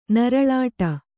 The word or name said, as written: ನರಳಾಟ